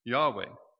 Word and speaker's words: Yahweh: That Hebrew word there, "I am," it comes from Haya, H-A-Y-A-H. And it's related to the personal name for God in the Old Testament, Y-H-W-H, often pronounced Yahweh, or the Eternal, or the One who always exists.